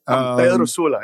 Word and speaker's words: Pedro Sula: Pedro Sur, Honduras, right? – Pedro Sula, yeah. – Pedro Sula, thank you. And the reason I'm making sure I pronounce that correctly is that is the most dangerous city in the world.